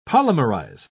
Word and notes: polymerize: British English

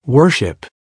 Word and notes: worship: American English

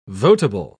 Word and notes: votable: British English

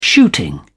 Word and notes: shooting: British English

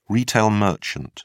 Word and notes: retail merchant: British English

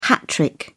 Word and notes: hat trick: British English

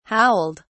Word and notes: howled: British English

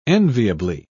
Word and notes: enviably: British English

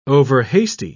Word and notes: overhasty: British English

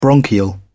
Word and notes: bronchial: British English

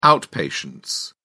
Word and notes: outpatients: British English

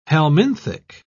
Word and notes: helminthic: British English

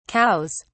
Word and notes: cows: British English

